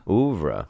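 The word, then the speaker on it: Œuvre: How do you say Ouvre? – Ouvre? – Ouvre. – Something like that? – That's it.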